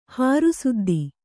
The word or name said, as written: ಹಾರುಸುದ್ದಿ